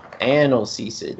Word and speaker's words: analgesic: It's pronounced analgesic. – Analgesic. Not analgesic.